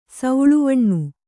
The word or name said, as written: ಸೌಳುವಣ್ಣು